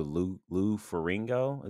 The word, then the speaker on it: Lou Ferrigno: I just knew Lou Ferrigno.